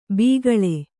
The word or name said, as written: ಬಕಾಳ